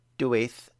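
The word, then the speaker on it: Dewi: Non named her son Dwaith, though local Dyfed pronunciation meant that he was commonly called Dewey.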